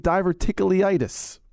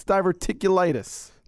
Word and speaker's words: diverticulitis: You're saying diverticulitis. It's diverticulitis. – Diverticulitis. – Not leitis. Diverticulitis. – Oh. – You're saying tickle. There's no tickle in diverticulitis.